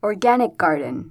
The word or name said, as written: organic garden